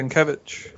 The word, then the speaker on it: Sienkiewicz: Bill Sienkiewicz, is that right? – Yes, that's right. – Yes, okay. Sienkiewicz.